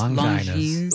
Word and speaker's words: Longines: It's Longines.